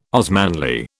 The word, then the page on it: Osmanli: British English